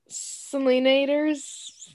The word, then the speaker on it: Selenators: Selenators.